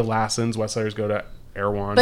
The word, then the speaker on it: Erewhon: And you can get the ingredients at Arrowhorn. How do you say that? – Erewhon. Yeah.